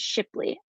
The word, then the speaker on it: Shipley: And my last name is Shipley, Shipley.